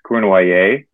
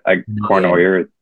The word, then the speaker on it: Cournoyer: It's Cournoyer. It's French, but Cournoyer is fine for the American audience.